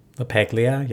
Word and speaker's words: LaPaglia: LaPaglia, yeah.